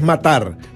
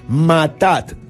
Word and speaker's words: matat: No es matar, matat, con doble T intermedia y T al final. Se escribe M-A-T-T, matat.